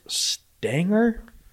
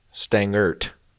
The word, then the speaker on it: Stangert: So there's Alvin, there's Stanger. – Stangert. Some people don't pronounce the T, like Voldemort, but I've always pronounced the T, so I say Stangert and I say Voldemort.